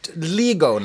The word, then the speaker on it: LIGO: LIGO now.